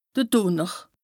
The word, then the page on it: de Dùnner: Bas Rhin; Strasbourg